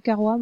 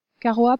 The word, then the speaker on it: Caroa: Caroa prononce son nom. Il dit Kawa, comme son père. Ils ont tous les deux du mal à prononcer les R.